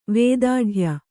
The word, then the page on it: ವೇದಾಢ್ಯ: Kannada